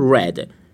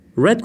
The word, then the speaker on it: read: I read. Read گذشته read هست. Read، read، read. شکل نوشتاریش برای هر سه زمان یکی هست، برای هر سه حالت، ولی تلفظش فرق میکنه.